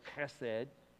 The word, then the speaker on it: Hesed: But, verse 15, my loving kindness, that's the word Hesed, or you pronounce it like you're clearing your throat, Hesed.